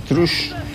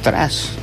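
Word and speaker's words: Trust: Este tema de los franceses, es original de los franceses, Trush. O tras, depende como la pronunciación que le quiera dar cada uno.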